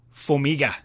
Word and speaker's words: Formiga: Third place, or joint third, Formiga. Am I pronouncing that correctly? – Yes.